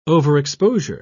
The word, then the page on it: overexposure: British English